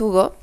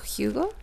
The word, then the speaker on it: Hugo: Y pues en realidad es Hugo, pero pues lo escribió una autora inglesa, entonces sería Hugo. – Ah, ok. a la hora de pronunciarlo.